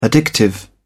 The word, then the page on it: addictive: British English